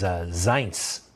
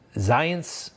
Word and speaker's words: Zients: His name is Zients. Zients, Z-I-E-N-T-S. Rhymes with science, I'm told.